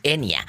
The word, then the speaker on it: Enya: Se escribe con Y, muchos dicen Enya, Enya, es Enya.